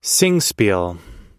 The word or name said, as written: singspiel